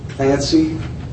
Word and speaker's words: Clancy: Yes, it's John Clancy, C-L-A-N-C-Y, Lavins, L-A-V-I-N-S, and my wife's name is Molly, M-O-L-L-Y.